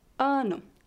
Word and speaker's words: ano: Ano. A letra O no final da palavra é fraca e soa como um U fraco.